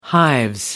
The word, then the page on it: hives: British English